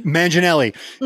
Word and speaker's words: Manganiello: I legitimately thought it was Sofia Vergara's husband when I saw the video, because he looks like, what's his name, Joe Mantiglione or whatever I say his name. – Manganiello.